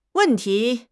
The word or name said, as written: wen4 ti2